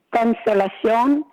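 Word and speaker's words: Consolación: Now, she had a sister named Consolacion. Am I saying that right? – Consolacion, but that was Matilde's sister.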